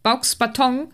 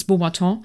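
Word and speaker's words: Beauxbatons: ähm, man lernt dann ja auch äh, eine weitere Schule im Prinzip kennen, die ich als Kind Bauxbaton natürlich ausgesprochen habe. Es ist Beaubaton.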